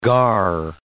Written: gar

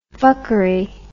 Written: fuckery

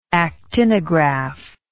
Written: actinograph

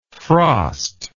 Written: frostlike

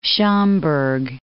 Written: Schaumburg

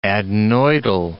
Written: adenoidal

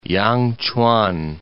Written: Yangchuan

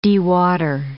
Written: dewater

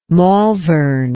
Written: malvern

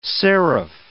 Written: seraph

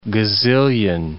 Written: gazillion

